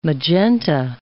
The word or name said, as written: magenta